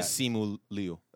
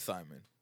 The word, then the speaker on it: Simu Liu: I've only heard it as Simon. – It's not Simon either. There's not an N.